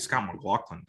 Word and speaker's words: Scott McLaughlin: Scott McLaughlin can.